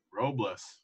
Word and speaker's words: Robles: I mean, how do you say his name? – Victor Robles? – Robles. Yeah, I like Robles.